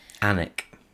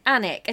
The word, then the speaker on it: Alnwick: Is it Alnwick? – Alnwick. – Alnwick, is that how you say it? – I think so, but then... – Yes.